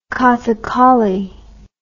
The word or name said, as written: kathakali